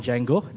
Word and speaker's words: Django: My wife, my two furry kids, Mamba and Django. The D is silent, if you get the joke.